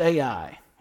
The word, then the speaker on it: Ai: They go to the next nation that they believe God is leading them to conquer, a little nation, different ways of pronounce it. I pronounce it Ai. It's spelled literally Ai.